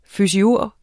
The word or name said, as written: fysiurg